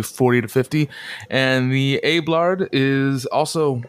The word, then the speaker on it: Abelard: Ablard, yeah, the Ablard. – How do you spell that last one? – A-B-E-L-A-R-D.